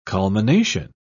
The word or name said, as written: culmination